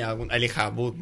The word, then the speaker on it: Elijah Wood: Elijah Wood. Bueno, perfectamente pronunciado, además.